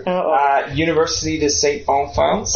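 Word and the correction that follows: Boniface: Bonifance. There you go.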